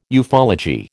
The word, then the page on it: ufology: American English